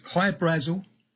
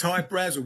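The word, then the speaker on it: Hy-Brasil: There's High Brazil. People pronounce it High Brazil, and it's Brazil. Okay. – Yeah, yeah. But it's High Brazel because that's how the Irish would pronounce it, because it's an okay.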